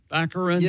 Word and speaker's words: Baccarin: Baccarin? – Baccarin.